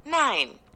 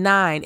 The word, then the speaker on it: nein: Nine. It sounds like the number 9, but it is spelled N-E-I-N. N-E-I-N.